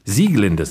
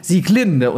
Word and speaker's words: Sieglinde: Sieglinde sehe ich hier gerade aus der Walküre, weil nämlich alles bei Wagner auf der ersten Silbe betont wird. Ja, also das wissen viele auch nicht. Und die alten Wagnerianer legen da sehr viel Wert drauf. – Ich mache es immer falsch. Ich mache nämlich immer Sieglinde und die Walküre.